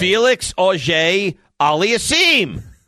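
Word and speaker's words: Félix Auger-Aliassime: Felix Auger Aliassime.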